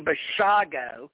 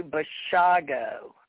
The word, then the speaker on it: Bashago: Anyway, Ken, all Andrew has to do, Andy, folks, Andy Bashago, I know, here's Andy told me himself, it's like Dr. Zhivago, all you people out there that are mispronouncing it. Andy is Andrew Zhivago, but it's like, it's Andrew Bashago, okay?